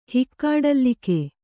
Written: ಹಿಕ್ಕಾಡಲ್ಲಿಕೆ

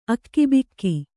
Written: ಅಗಿಬಿಗಿ

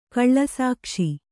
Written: ಕಳ್ಳಸಾಕ್ಷಿ

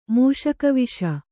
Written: ಮೂಷಕವಿಷ

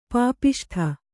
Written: ಪಾಪಿಷ್ಠ

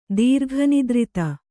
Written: ದೀರ್ಘನಿದ್ರಿತ

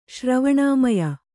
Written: ಶ್ರವಣಾಮಯ

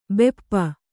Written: ಭೂಪ

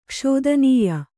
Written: ಕ್ಷೋದನೀಯ

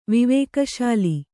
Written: ವಿವೇಕಶಾಲಿ

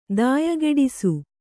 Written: ದಾಯಗೆಡಿಸು

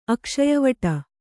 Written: ಅಕ್ಷಯವಟ

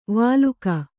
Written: ವಾಲುಕ

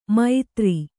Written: ಮೈತ್ರಿ

